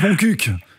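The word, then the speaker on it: Montcuq: Et puis il y a le Lot-et-Garonne en dessous, il y a mon cul, le fameux village. – Ça se prononce mon cuc, en fait.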